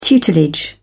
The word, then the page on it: tutelage: British English